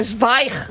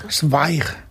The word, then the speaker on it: Zweig: Zweig. – Zweig.